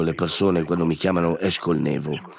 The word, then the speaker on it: Eshkol Nevo: La prima si chiama Eshkol Nevo, questa è la pronuncia originale, ma poi ho una seconda personalità che risponde invece alla pronuncia in italiano, che pure mi piace molto. Eshkol Nevo, così mi chiamano in Italia, e mi piace.